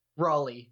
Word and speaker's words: Raleigh: How is it pronounced? – Raleigh. – There we go.